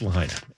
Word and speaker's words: Lahaina: I'm going to try to keep pronouncing it correctly. When I went there a couple of times, and for some reason, after visiting it twice, that the other pronunciation stuck in my head. I don't know. But it's Lahaina. And Steve is here because he got a tour of some of the fire-ravaged terrain.